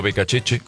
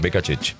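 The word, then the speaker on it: Beccacece: Está hablando Becacheche, así se pronuncia, Becacheche.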